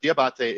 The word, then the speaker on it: D'Abate: So it's pronounced either way, but Diabate is really the traditional pronunciation.